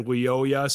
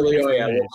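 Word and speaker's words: Lyoya: I heard a family, one of his aunts said Leoya, so I'm going Leoya. Leoya. Okay. – But I have heard it. – So Leoya, we'll go with that.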